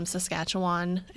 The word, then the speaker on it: Saskatchewan: Stu Hart was a professional wrestler from Saskatchewan. Am I saying that right? Yes. – Yeah, it sounds right. I've heard it said that way.